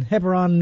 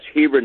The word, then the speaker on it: Hebron: And now let's go to Hebron, New Hampshire, if I said that correctly. You're on with Mark. – Yeah, hi, Mark. That's Hebron, New Hampshire, but that's fine.